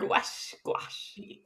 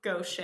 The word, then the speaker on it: gouache: Gouache. – Exactly. – It rhymes with squash.